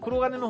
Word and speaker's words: 鋼鉄: あ、黒金の方向、黒が、読み方間違えてたかな。黒金の方向だ。そう、黒金の方向ですね。